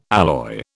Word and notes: alloy: British English

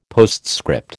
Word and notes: postscript: American English

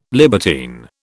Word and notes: libertine: British English

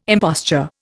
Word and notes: imposture: Indian

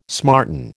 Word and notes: smarten: American English

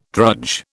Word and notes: drudge: British English

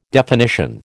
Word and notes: definition: American English

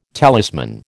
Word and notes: talisman: American English

